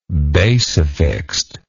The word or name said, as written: basifixed